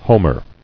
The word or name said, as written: Homer